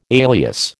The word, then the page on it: alias: American English